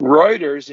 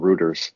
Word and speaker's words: Reuters: Reuters, and you said Reuters last week.